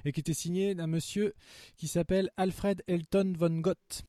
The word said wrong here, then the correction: van Vogt: Ah oui, je connais Van Vogt. – Voilà. Van Vogt. Van Vogt.